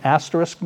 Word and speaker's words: asterisk: And by the way, that word is asterisk. Did you all know that? That's how it's pronounced. It's not asterisk. People sometimes say asterisk.